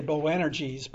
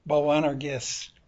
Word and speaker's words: Boanerges: Now, you probably say Boanerges, but that's your poor English pronunciation of the Greek word Boanerges, which means sons of thunder.